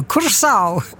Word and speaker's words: Curaçao: Då vet ju du hur man uttalar landets namn. – Ja, man säger Curaçao.